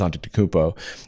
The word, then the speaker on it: Antetokounmpo: Giannis Antetokounmpo. But, oh, is that how you say it? – Yeah.